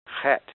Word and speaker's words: Chet: Now that CH is pronounced like a guttural H or a guttural K, the kind of sound that you will find in German and in Scotch dialect. Ch. So it's Chet.